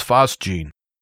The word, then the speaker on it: phosgene: It's spelled P-H-O-S-G-E-N-E. Phosgene is how I'm going to pronounce it.